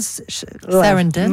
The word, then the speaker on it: Sarandon: Vous voulez nous parler de Susan Sarandon? – Merci.